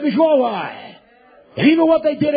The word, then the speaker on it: Yahweh: There's only one way. I'm telling you how to pronounce it. Yahweh.